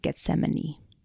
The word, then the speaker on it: Gethsemane: And he was like, go read about Jesus in the Garden of Gethsemane. – Well, good job. – You like how I said that word? Yeah, I said it right.